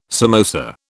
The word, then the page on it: samosa: British English